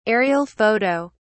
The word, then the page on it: aerial photo: British English